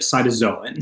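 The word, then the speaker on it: Cytauxzoon: I don't know how to pronounce it. Cytoxon? – Yep, cytozoan. Cytozoan.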